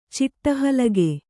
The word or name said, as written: ಚಿಟ್ಟಹಲಗೆ